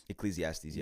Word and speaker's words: Ecclesiastes: Yeah, bro, like you were saying, it's in a, how do you say, Ecclesiastes? – Ecclesiastes, yeah.